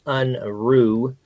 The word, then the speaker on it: Unruh: So help me pronounce the last name. Unruh, is that right? – It's close. I've been called many things. The correct pronunciation is Unruh. – Unruh. Got it. – German for unruly, but I'm actually a real nice guy.